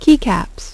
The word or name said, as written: Key Caps